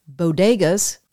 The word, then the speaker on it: bodegas: There's no word on whether anyone in the White House has actually sat down and told the First Lady that the word she used is pronounced bodegas, not what she said.